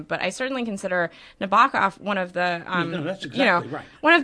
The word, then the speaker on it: Nabokov: Not Nabokov, as he said, rhymes with knockoff.